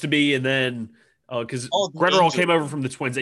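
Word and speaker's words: Graterol: Bruce Stark Gratterall? – Gratterall, yeah.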